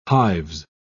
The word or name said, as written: hives